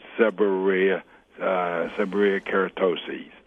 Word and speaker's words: seborrheic keratosis: What you have, and trying to pronounce, is called seborrheic keratosis. S-E-B-O-R-R-H-O-I-C, seborrheic keratosis, K-E-R-A-T-O-S-I-S.